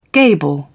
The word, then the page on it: gable: British English